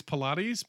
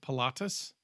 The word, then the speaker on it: Pilatus: I want to say it's Pilates, but it's not. It's Pilatus, PC-21 and nice.